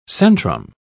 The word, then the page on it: centrum: British English